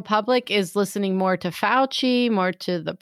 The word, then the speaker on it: Fauci: But I have noticed that sometimes, so in the States, are they pronouncing it Fauci or Fauci? – No. We are pronouncing it the correct way, Fauci.